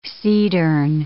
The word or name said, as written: cedarn